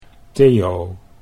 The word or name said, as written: Theo